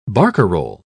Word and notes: barcarolle: British English

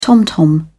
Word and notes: tom-tom: British English

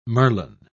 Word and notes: merlon: British English

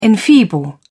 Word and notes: enfeeble: British English

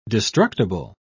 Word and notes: destructible: British English

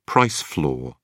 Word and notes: price floor: British English

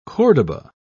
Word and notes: cordoba: British English

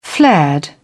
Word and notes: flared: British English

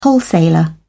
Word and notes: wholesaler: British English

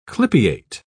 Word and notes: clypeate: British English

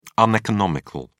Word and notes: uneconomical: British English

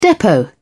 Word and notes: depot: British English

